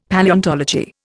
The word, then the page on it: Palaeontology: Indian